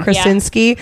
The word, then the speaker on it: Krasinski: Apparently John Krasinski, is that how you say it? – Mm-hmm. – Krasinski, yeah.